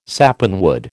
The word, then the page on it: sapanwood: American English